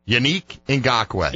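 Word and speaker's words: Yannick Ngakoue: So Irish Jag asks, so is Unique pronounced like unique, except like Yannick? Yes, it's kind of like that. The Neek part is the same, apparently. Unique Ngakoue. – Yeah. – Get it straight. – Sounds like the stress is on the ya.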